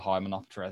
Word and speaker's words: Hymenoptera: There's the man who floats like a Lepidoptera and stings like a Hymenoptera. There you go, Key. Mutual. – I am so impressed at your pronunciation of Lepidoptera and Hymenoptera. – Thank you. – That rolled off your tongue, mate.